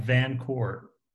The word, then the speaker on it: VanCort: Make sure I get this right, VanCort.